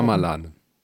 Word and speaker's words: Shyamalan: Wie, war, wie wird er ausgesprochen? – Schamalan. – Schamalan äh, sind.